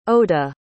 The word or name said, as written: oda